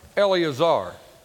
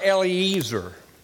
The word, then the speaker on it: Eleazar: Eleazar. Not Eliezer. Because I always pronounced it that way until I got to Israel.